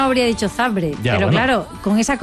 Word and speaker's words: Zabrze: ¿Por qué pronuncias Zabrze? – Porque es así.